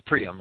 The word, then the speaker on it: Priam: Quote, Homer relates that Troy reached its greatest splendor in the reign of King Hiram. – That's Priam, I'm sorry. – Was you going to say something? Or? – Oh, that's King Priam. – You pronounce it, Priam, okay.